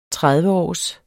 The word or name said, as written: trediveårs